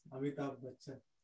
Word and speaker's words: Amitabh Bachchan: Yes, Amitabh Bachchan.